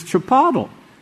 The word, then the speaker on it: Chipotle: Sister Matthews, she's trying to pronounce that word, and I said, it's Chipotle.